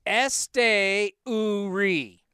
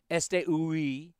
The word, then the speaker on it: Esteury: She's she gave me the Esteury. And then now you can see it how phonetically it's Este Uri. Yeah. Este, what's capital O O? Ooh. Ooh. Esteury.